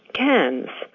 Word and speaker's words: Cairns: Americans seem to have a very hard time pronouncing this name. They say Cairns or Cairns, but it is actually Cairns.